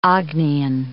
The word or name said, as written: Agnean